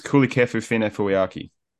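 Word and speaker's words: Kulikefu Finefeuiaki: I'll pronounce it. It's Kulikefu Fine Fuiaki.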